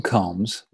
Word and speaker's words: Combs: William Combs, with two O's, Melanie Bollinger told her father over long distance, pronounced just like the thing you comb your hair with.